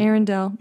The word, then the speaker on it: Arendelle: This film follows Olaf as he comes to life and searches for his identity in the mountains of Arendelle. Is that how you pronounce it? – Arendelle.